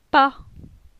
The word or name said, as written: Pa